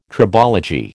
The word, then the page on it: tribology: American English